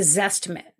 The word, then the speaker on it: Zestimate: You mentioned earlier about the Zestimate. Did I pronounce it right? – Yes.